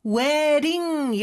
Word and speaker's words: wedding: wedding 이렇게 발음이 되는데 d 발음은 모음 소리 사이에 있어서 real처럼 발음이 돼요.